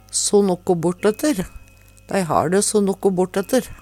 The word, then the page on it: so nokko bortetter: Numedalsmål